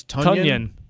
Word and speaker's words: Tonyan: Now it's Tonyan. – Like Funyan. Like the chip. Funyan. It's Tonyan. Robert Tonyan. All right, so Davante Adams, 13 receptions. Jamal Williams, 4. Robert Tonyan, 2.